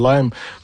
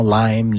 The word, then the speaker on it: Lyme: D'ailleurs, je mélange toujours avec la prononciation Lyme, Lyme. J'ai été voir, ça vient de la municipalité, de la localité de Old Lyme dans le Connecticut où on a trouvé cette maladie-là pour la première fois. C'est pour ça qu'on dit maladie de Lyme, L-Y-M-E. Mais je vois que la prononciation francisée, le Lyme, a été de plus en plus acceptée.